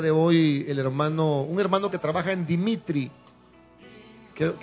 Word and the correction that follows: Dimarti: Se, se llama Dimarti, la empresa. Dimarti.